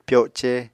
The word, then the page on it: pyoce: Apatani